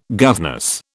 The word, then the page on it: governess: British English